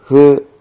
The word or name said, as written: hu